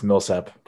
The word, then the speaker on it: Millsap: Um, but I look at like players like Paul Mishap. That's that's veteran experience that the uh, is what I did, I did I say it wrong? – It's Millsap, Paul Millsap.